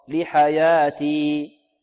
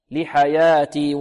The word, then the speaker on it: لحياتي: يقول يا ليتني قدمت لحياتي، الوقف على حياتي هنا نقف على الياء بمقدار حركتين، لا نزيدها أكثر من حركتين، لا ننطقها هكذا. يقول يا ليتني قدمت لحياتي، خطأ، لحياتي لحياتي.